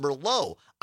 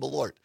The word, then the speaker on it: Malort: So Malort is, and somebody, Julie or Jules in Mozanie, listening on WXCO, says, are you sure it's not pronounced Merlot? I actually looked it up, Jules, because I didn't know, and that's why I found that video of this guy reviewing it. And no, this is, it's spelled differently than Merlot. It's M-A-L-O-R-T, and it's pronounced Malort.